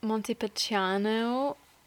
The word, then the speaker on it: Montepulciano: I'm from Montepulciano, Ooh, where good pronunciation.